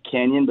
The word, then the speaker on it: Kanyon: You know, it's what happens when your parents decide to name you Kanyon, but then they put a K on there.